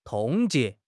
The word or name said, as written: 冻结